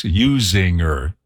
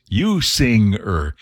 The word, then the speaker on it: Usinger: I used to say it's Usinger. Called me on the radio one time, request line. Hey, dude, you're saying it wrong. It's Usinger. It's put a sing in there.